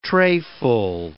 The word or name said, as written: trayful